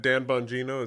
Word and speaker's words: Bongino: Even though I think he would qualify, but I think Dan Bongino is that how you say his name? – Yes, he's a strategic investor and partner as well.